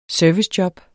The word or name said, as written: servicejob